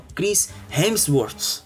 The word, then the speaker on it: Chris Hemsworth: É o Chris Hemsworth. Eu acho que é isso, eu acho que eu falei certo. – Olha! – É, é exato.